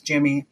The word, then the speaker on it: Jimb: So instead, he made his nickname Jim, J-I-M-B. Because it's spelled like limb, where the B is silent.